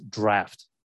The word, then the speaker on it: draught: I think the pronunciation is draft, but it's spelled like draught.